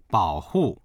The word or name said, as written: bao3hu4